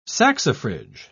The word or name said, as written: saxifrage